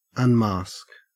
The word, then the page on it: unmask: Australian English